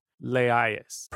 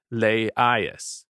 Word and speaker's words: Laius: Laius, pronounced as three syllables, Laius, the last king of Thebes before Oedipus.